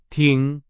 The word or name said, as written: 挺